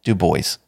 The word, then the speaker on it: Du Bois: And yes, his name is pronounced Du Bois, so don't give me any shit about that one, guys.